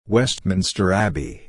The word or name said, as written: Westminster Abbey